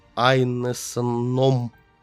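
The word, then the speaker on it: Ineson: Айнесоном. – Вот так он читается.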